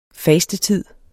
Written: FASTETID

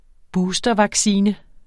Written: boostervaccine

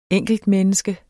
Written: enkeltmenneske

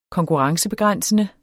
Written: konkurrencebegrænsende

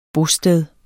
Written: bosted

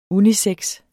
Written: unisex

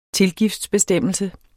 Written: tilgiftsbestemmelse

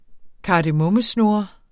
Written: kardemommesnurre

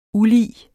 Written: ULIGT

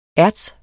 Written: ERTSEN

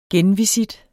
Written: genvisit

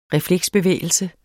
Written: refleksbevægelse

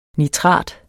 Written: nitrat

